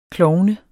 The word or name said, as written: klovn